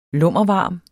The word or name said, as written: lummervarme